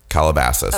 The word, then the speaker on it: Calabasas: Casalaba. How do you pronounce that? Calabasas. – Oh, Calabasas. Britney Spears' Calabasas residence at approximately 11:30 p.m.